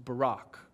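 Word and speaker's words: Barak (בָּרַךְ): In the Hebrew, the word sounds just like our president's first name, Barak. That's, that's how the, the Hebrew word is pronounced, Barak.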